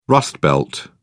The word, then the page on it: rustbelt: British English